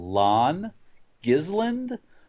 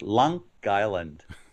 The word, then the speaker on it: Long Island: And the name of the EP is to people who aren't familiar with the New York metropolitan area, it might look like it's titled Lawn Gisland, but to the rest of us, it's pronounced Long Island.